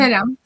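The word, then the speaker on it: Ceram: Bueno, com es pronuncia en alemany? – Sí. – El Seram en alemany? – Seram. – Seram, tal qual.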